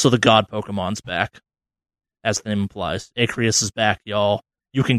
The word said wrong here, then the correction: Arceus: Is it Arceus? – Arceus, you're right. Arceus, that's the correct way of pronouncing it.